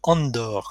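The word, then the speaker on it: Andor: Andorre, comme on prononce.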